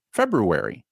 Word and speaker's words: February: Even earlier, when I said what day it was, I had to say February. I put an R in February. Do I have to? Do most people do? No, but my brain says there's an R there and it's supposed to be pronounced that way.